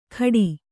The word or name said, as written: ಖಡಿ